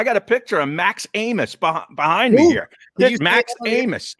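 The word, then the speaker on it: Abmas: It's spelled Abmus, but it's pronounced Amos for some reason.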